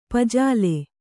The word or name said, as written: ಪೂಜಲ್ಲಿ